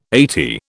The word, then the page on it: eighty: British English